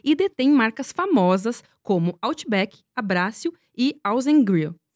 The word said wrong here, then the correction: Aussie Grill: Falei certo? – Aussie Grill. – Aussie Grill, perfeito.